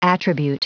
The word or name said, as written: attribute